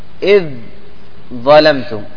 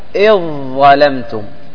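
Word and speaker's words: إذ ظلمتم: طيب تكون على موضعين، اولا الذال في الضاء، الذال في الضاء، نحو اذ ظلمتم. اذ ظلمتم كيف ننطقها؟ اذ ظلمتم. اذ ظلمتم تقم معي؟ اذ ظلمتم.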